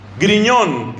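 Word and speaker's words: Grignion: Entonces hay un santo que quizá usted se pueda hacer amigo de él, que se llama Luis María Griñón, que se escribe Grignón con GN.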